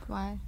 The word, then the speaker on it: Kauai: Is it a place called Kauai? – Mm-hmm. – Yeah. – Kauai.